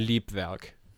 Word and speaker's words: Leibwerk: Leibwerk. German pronunciation Liebwerk.